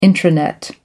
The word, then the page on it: intranet: American English